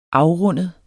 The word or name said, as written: afrundet